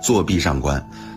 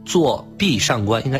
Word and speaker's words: 坐壁上观: 今天我们经常会说坐壁上观，就是说二二这么分。那就是说我刚才这个发音是有错误的，是吗？节奏是有问题的。 – 是的，应该是坐壁上观，应该一三这么分。